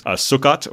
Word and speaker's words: Sukkot: So I get to celebrate the 7th day of what's known as Sukkot, I think would be the correct pronunciation, which is just the Hebrew word for tabernacle or booth.